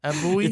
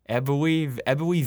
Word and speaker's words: Ebbw: how do you pronounce this? E-B-B-W. – Is it Ebwy? – It's Welsh. Ebwy Vale, South Wales, in 1980.